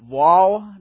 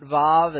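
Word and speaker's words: waw: And according to Brian Murphy, Dr. Barrick took his Hebrew from Moses himself, and he pronounces it Wal, not Vav. And so we'll go with Wal because that seems to be closer to the source.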